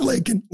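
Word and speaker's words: Laken: He said Lincoln Riley, couldn't even get her name right, was forced to say it by Marjorie Taylor Greene and said Lincoln, not Lakin, Lincoln.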